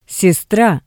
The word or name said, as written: sestra